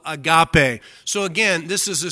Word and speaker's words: agape: Jesus is telling us to have an agape love for our enemies. A-G-A-P-E. Agape, or some pronounce it agope.